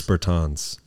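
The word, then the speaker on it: Bertāns: And Davis Bertrand. – Davis Bertans.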